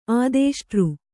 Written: ಆದೇಷ್ಟೃ

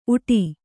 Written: ಉಟಿ

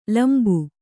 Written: ಲಂಬ

